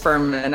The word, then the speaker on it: Fuhrman: Just Fuhrman, actually. So you were close.